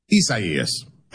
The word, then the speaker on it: Isaias: But do you say Isaias or Isaias? – Isaias. – All right, Isaias.